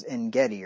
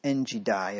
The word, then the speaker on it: En-Gedi: If you didn't have the pronunciation marks that I have, you'd think it was En-Gedi or something, but it's En-Gedi if you had a Cambridge anyway.